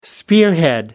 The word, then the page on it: spearhead: American English